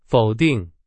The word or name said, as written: 否定